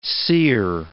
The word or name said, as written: seer